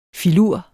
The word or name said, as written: filur